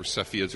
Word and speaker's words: Cepheids: So she found these stars called the Cepheids or Cepheids. There's a bunch of different ways to pronounce it.